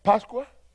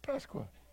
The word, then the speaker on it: Pascua: This is, and even in Spanish, I think the word is Pascua. Did I say it right? I said that right. I said it with an accent. Yeah, Pascua, isn't it? Pascua.